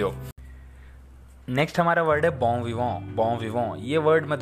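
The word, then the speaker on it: bon vivant: बॉम विवों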